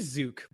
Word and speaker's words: Zook: And so now I'm Jason Zook or Zook. It's totally cool if the Canadians say Zook because that is phonetically how it probably should be pronounced.